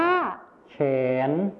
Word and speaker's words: แขวน: คำว่าแขวนในภาษาถิ่นอีสานจะออกเสียงว่าแขน